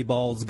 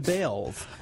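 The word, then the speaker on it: Baals: After all, the family did change the pronunciation of their last name from Balls to Bales. – Okay, hold it. There's key point here. – What? – They changed their name from Balls to Bales. – Not the spelling, just the pronunciation.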